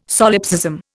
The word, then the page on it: solipsism: Indian